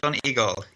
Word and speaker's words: Donegal: Am I saying that right? – Almost, almost. Donegal. Donegal. Yeah, in Ireland.